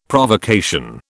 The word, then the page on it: Provocation: British English